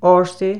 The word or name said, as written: ORSAY